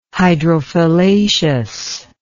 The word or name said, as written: hydrophyllaceous